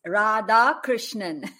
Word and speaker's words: Radha Krishnan: It's four syllables. It's Radha Krishnan.